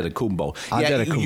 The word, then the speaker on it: Antetokounmpo: Antetokounmpo. Is that right? Am I saying it right? – Antetokounmpo. You emphasize it's more the, you said it right, but it's more the emphasis of the syllable.